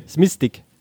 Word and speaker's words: Smistik: Die Tore für Rapid schoss ein Matthias Kolosek und ein Josef Sinistik oder so. – Wie? Smistik. – Smistik, ja.